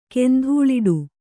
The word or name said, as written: ಕೆಂಧೂಳಿಡು